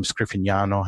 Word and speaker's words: Scriffignano: My name, Scriffignano, has a GN sound in it, the nya, that's not an English phoneme. So, you know, when I tell people how to say it, I say, well, say lasagna, because you already know how to say that, right?